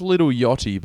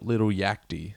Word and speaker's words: Yachty: Or I think it's Lil Yachty, but it's spelt Lil Yachty.